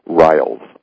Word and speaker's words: Riles: I'm Phyllis Pricer, your hostess, and I have with me a wonderful guest today, and just the way he spells his name, I want to say Reels, is that correct, or is it Riles? – It's actually Riles.